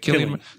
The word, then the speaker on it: Cillian: Killian, Killian.